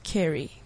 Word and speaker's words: Ceri: C-E-R-I. – Well, yeah. – If she's the same one that was on the Unview, it's pronounced Cherry.